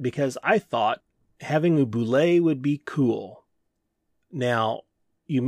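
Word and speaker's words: bulette: And I say boule because that's how Tim Kask, the man who invented the boule, pronounces it. We used to call it a bullet. Some people call it a bullet. Well, boule is Tim Kask's preferred pronunciation. I think we should honor the creator of said monster and go with his pronunciation.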